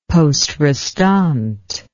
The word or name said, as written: posterestante